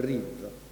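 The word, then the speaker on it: Riv (ריב): È scritto Rib, ma si pronuncia Riv.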